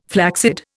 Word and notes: flaccid: Indian

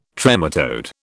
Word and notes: trematode: British English

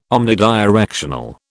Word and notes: omnidirectional: British English

